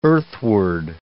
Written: earthward

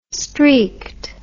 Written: streaked

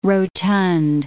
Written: rotund

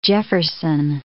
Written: Jefferson

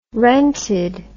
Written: rented

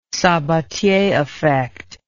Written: Sabattier effect

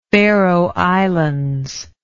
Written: faeroe islands

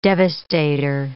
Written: devastator

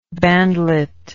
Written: bandlet

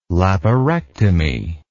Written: laparectomy

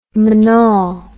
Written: monal

